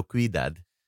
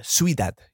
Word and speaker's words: Ciudad: Oh, you've gone Ciudad? Because I couldn't work out whether it was Ciudad or Cuidad. You've gone Ciudad. – I went. – Ciudad? – Ciudad, yeah.